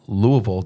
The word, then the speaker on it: Louisville: And now all three went off to college together at Boyce College Southern Seminary up in Louisville. Did I say it right? – Mm-hmm. Yeah, that's right. – Okay, yeah. So not Louisville. – No. – Louisville, Kentucky. Yep.